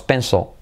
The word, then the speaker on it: pencil: If you drop your pencil, fijaos, pencil, L al final de palabra, casi no se pronuncia, pencil.